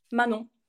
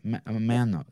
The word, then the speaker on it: Manon: Manon. – Manon. – Yes. – Mano. So don't even pronounce the N at the end. Mano.